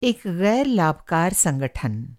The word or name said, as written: संगठन